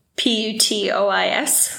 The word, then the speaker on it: Putois: Putois. – That's a pretty good pronunciation.